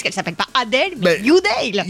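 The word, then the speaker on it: Adele: On dit Adèle, forcément, hein, oui. – on le dit comme ça est écrit, mais en vrai, la prononciation serait Youdale. – Youdale. – Oui, donc pas Adèle, Youdale.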